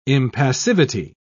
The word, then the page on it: impassivity: British English